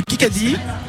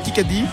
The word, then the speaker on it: Kikadi: Le Kikadi, parce que ça se prononce comme ça, c'est le Kikadi.